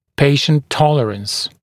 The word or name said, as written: patient tolerance